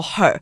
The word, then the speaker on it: HUR: 女团的时候，我们就觉得我们要沿用我们的这个初心，Hurricane，嗯，我们选择它的缩写 H U R，那同时也念作 Her，就跟女生的 Her H E R 有一个同音，就是女力的意思。